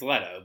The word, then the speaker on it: Leto: Leto. It's Leto, but I don't know if they pronounced that way.